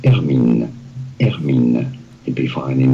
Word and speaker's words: Hermine: They just have H-E-R-M-I-N-E, Hermine. – Yeah, Hermine, exactly. Hermine. Let's see if Google, how do you, Google may do it, pronounce H-E-R-M-I-N-E.